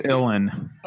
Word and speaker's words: Ilan: Back to the, I always mispronounce the name. How do you pronounce Ian Rubin? How do you pronounce the drummer? – I think it's Ilan. – Oh, Ilan. Yeah.